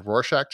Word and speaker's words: Rorschach: It's a Rorschach test.